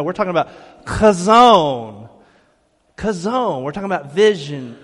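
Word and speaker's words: chazon (חזון): You know, if you dig into the Hebrew of that proverb, that word that we see is vision or revelation, the Hebrew pronunciation is chazon. Can you say that with me? Chazon. You got to get guttural with me. Chazon. Almost like you're coughing on someone, but don't cough on anybody, all right?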